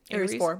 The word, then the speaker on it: Ares: Ares 4.